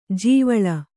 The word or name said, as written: ಜೀವಳ